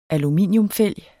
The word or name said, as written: aluminium